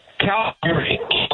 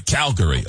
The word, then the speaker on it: Calgary: Calgary. – Calgary. Calgary. Okay. Because I've heard it as Calgary.